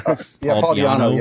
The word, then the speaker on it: Di'Anno: Paul Di'Anno.